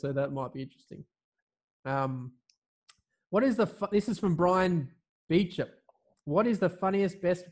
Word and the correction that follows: Beauchamp: Was that Brian Beauchamp? You might have butchered his name completely. – I butchered it, man. Beauchamp. Beauchamp.